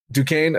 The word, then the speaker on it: Duquesne: Duquesne.